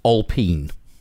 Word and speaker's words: Alpine: Yes, Alpine, although it's pronounced Alpine because France.